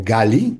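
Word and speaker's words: Gali (גלי): Algunos nombres femeninos pueden ser analizados también bajo esta fórmula de inflexión como Galí o Gali, que así se pronuncia, que es mi ola, Carni, mi rayo.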